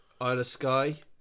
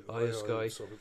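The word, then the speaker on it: Iyo Sky: How do you pronounce her name? Dakota Kai, but Isla Sky. – Isla Sky.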